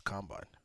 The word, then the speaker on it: combine: Combine. That's how you say it.